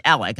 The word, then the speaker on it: Alex: Alex, it's pronounced Alec, I guess, was a golden child who sired golden children, born in 1967.